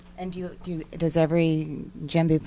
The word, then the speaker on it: djembe: And can you pronounce it again? A djembe? – Djembe. – A djembe? – Yes.